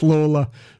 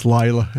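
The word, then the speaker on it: Lalor: it's L-A-L-O-R, which Irish people pronounce Lawler, but I think it gets pronounced Layla in Australia.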